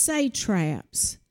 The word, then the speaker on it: satraps: So he appointed these 120, now they're pronounced satraps, and three presidents to direct the affairs of Babylon.